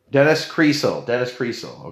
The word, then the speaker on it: Creasel: Sill, like a window sill. Dennis Cree window sill. Dennis Creasel.